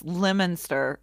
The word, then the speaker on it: Leominster: But anyway, it's spelled Leominster or something like that. And it's Lemonster or something.